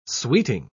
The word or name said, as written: sweeting